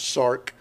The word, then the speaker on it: sarx: Now, the Greek word that's translated as flesh spelled out as S-A-R-X, but it's pronounced sark.